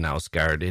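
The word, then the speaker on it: Knausgaard: Secondly, the K is pronounced in Nausgaard. It is not silent, and it's not pronounced Nausgaard guard with a long A sound. The A has a different sound than ah, as you pronounce it, but it's a mistake much easier on the ears of a Scandinavian than not pronouncing the K.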